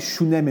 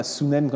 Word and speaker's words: Shunem: Et voilà comment la rencontre entre cette tsunamite, shunamite si je voulais le prononcer de manière hébraïque, c'est Shunem et non pas Sunem comme je l'ai prononcé, et eh bien cette Shunamite est euh, le prophète Élisée.